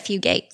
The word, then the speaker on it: Fugate: And I believe that's how you say it, Fugate. – I've always heard it Fugate, yeah.